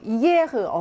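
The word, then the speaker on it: hier: 이 이에흐는 지금 이 다음에 이렇게 모음이 지금 연달아서 나오고 있죠. 그러면 모음이 모음과 만나면서 모음의 체력이 조금 약해지죠. 그래서 우리가 반모음이다라고 해서 발음이 이가 아니고 이렇게 발음이 약간 좀 세력이 약화되는 거죠. 이게 이런 발음이 나는데 그거를 고르면 되는 거예요.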